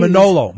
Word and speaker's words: Manolo: Manolo Blahniks.